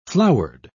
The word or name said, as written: flowered